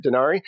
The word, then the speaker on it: Dresner: Since you're talking about spirits, let's talk about Dresner, right? Is that the right way to say it? Dresner? – Oh, yeah.